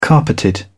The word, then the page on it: carpeted: British English